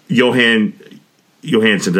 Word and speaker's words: Jóhann Jóhannsson: One is Adam, um, yeah, and and the epic and the absolutely phenomenal uh, uh Jóhann Jóhannsson. Did I pronounce his name right? Uh huh.